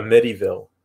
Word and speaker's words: Amityville: I always read it as a medieval, a, Amityville, Amityville. – It's not a medieval.